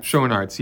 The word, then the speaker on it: Schoenaerts: How do you say the actor's name? Matthias Schoenaerts. – Schoenaerts, yes.